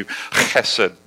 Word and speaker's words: chesed: We're going to do chesed. It's H-E-S-E-D, but that H is like a hard C-H. It's a guttural, almost like spit on the person in front of you. Chesed, all right?